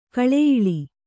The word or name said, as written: ಗುಳೆಯೇಳು